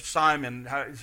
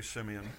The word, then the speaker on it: Simeon: This morning, we're going to look at the last song, which is by a guy by the name of Simeon, or Simon. People pronounce it different ways. I want to say Simeon.